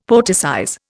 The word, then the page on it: poeticize: Indian